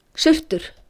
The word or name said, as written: Surtur